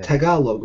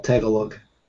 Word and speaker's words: Tagalog: Tagalog, right? Is the right way to say it, not Tagalog.